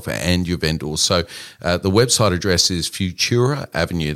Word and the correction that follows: Futuro: That's Futuro, I mispronounced it. Don't listen to me at all. Futuro, F-U-T-U-R-O, Avenue, as you'd expect Avenue to be spelt, but it's all one word.